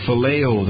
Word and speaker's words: phileo: That less strong word is pronounced phileo.